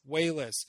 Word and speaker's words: Walas: No, Chris – Wayless. – Wayless.